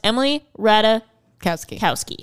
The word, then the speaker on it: Emily Ratajkowski: Sorry, it is pronounced Emily Ratajkowski.